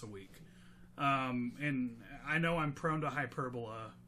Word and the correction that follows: hyperbole: Hyperbole? Did I say it right? – Hyperbole. – Hyperbole. There we go.